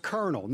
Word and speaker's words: Colonel: Explain why Colonel is pronounced Colonel. Now, it's spelled C-O-L-O-N-E-L, but why is it pronounced Colonel, K-E-R-N-E-L? – Okay, and my answer is, I don't know.